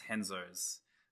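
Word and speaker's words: Renzo's: It's pronounced Henzo's.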